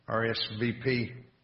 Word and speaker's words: RSVP: We know that if you get a special invitation, RSVP, as I've spoken before, you don't put a special invitation marked RSVIP. Am I saying that right? Yes. RSVP.